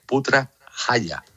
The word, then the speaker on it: Putrajaya: Sí, está en la ciudad de Putrajaya, lo he dicho bien, Putrajaya.